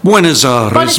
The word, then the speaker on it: Buenos Aires: It's Martin Palmieri's Misa e Buona Aires. Have I said it correctly? – Buenos Aires. Misa Buenos Aires.